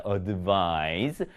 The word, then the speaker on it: advise: 지금 여기 앞에 있는 se로 끝나는 것은요, 발음이 advise가 되고요. 이 발음을 끝납니다. advise. 뒤에 있는 c로 끝나는 것은 advice. 이 발음으로 끝나게 되겠죠.